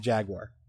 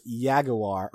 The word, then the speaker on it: jaguar: It might not have been the original, but the current and correct pronunciation is jaguar. And on top of that, if you're the kind of person that's going to hear somebody else say jaguar and then say it's pronounced yaguar or whatever the fuck you want, I reserve the right to punch you in the face.